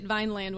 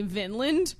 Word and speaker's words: Vineland: Somebody at a bookstore told me I pronounced it Vineland when I asked him for a copy, and then he repeated back to me Vinland, and I don't think it's right. – I don't think so either.